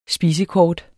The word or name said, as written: spisekort